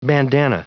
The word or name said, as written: bandana